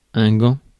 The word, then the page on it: gant: /ɡɑ̃/; France